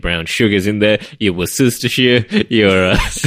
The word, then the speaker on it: Worcestershire: Mostly Worcestershire. That's not Worcestershire. – That's not how you pronounce that word.